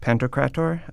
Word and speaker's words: Pantocrator: Well, in my parish, instead of a crucifix above the altar, we have a big icon of Christ Pantocrator. I hope I'm saying that right. – Yeah. But can you say that again? – Christ Pantocrator.